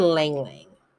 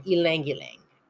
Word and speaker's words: Ylang Ylang: Sandalwood and Ylang Ylang. No, sorry, Ylang Ylang. Mispronounced it.